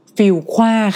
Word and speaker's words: Fuqua: ฟิลคว้าค่ะอันนี้ต้องบอกว่าต้องอ่านดีๆเลยนะมันสะกดอย่างนี้ค่ะมันสะกดว่า FUQUA ค่ะมันสะกดแบบเราอาจจะอ่านพลาดได้อ่ะคือต้องบอกเลยว่าเพื่อนเนี่ยที่ไปเรียนที่ฟิลคว้านะคะวันแรกที่ไปเรียนในชั้นเรียนเนี่ยเขาสอนก่อนเลยว่าชื่อมหาลัยอ่านว่าอะไร